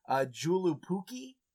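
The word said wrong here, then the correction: Joulupukki: Yalupuki. Yalapuki, yeah. Yalapuki? Like the J would probably be more of a Y sound, but yeah.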